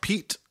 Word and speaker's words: Peete: It's spelled like P-E-E-T-E. – Yeah. – So like... – Like Amanda Peet.